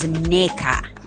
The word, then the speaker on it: Ndeka: No, it is written as Ndeka, but pronounced as Neka.